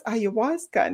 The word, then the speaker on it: ayahuasca: And she said that it was ayahuasca. And this is how you need to pronounce it, because all I saw, I had a spelling of it.